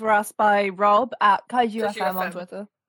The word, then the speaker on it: Kaiju: Is it pronounced Kaiju? – Yeah, Kaiju. – Kaiju. Rob from Kaiju FM. Yeah, like the Pacific Rim film.